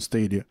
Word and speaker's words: Stadia: I said, Stadia, please, when you're releasing, because Doom was the very first big title to be released on Stadia.